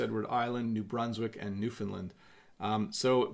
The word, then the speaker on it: Newfoundland: Game Account says, how do you really pronounce Newfoundland? So It's Newfoundland. That's a question coming out of the chat, but we say Newfoundland.